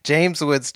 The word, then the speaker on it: James Woods: During the wedding, I really like, they have like this really ominous, like you just hear like James Wood talking. James Woods. Gosh, I can't say his name. James Woods talking over the wedding.